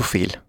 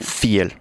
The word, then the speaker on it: viel: Con F, ¿no? – Con una F muy pronunciada. – Ajá. – Viel. – Fiel. – ¿Vale? – Entonces, sí, porque cuando lo vemos escrito, está escrito con V, ¿no? – Exactamente. – Ajá. Se escribe con V, pero se pronuncia como una F bastante pronunciada.